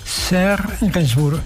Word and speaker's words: Serge Gainsbourg: Escrito por Serge Gainsbourg. ¿Lo pronunciaba bien? Serge Gainsbourg, perfecto.